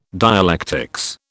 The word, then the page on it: dialectics: British English